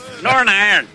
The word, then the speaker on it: Northern Ireland: N-O-R-N and then I-R-N. – Northern Ireland. – That's how you pronounce Northern Ireland. – Northern? – Northern Ireland. Nor an iron.